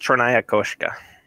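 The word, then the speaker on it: Chernaya Koshka (Чёрная кошка): It's, it's I believe it's pronounced Chernaya Koshka, which is Russian yeah.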